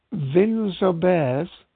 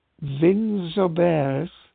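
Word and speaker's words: Vinsobres: The villages of Vins-Aubers, and I'm going to spell that because I'm awful at these pronunciations. It's V-I-N-S-O-B-R-E-S. Vins-Aubers.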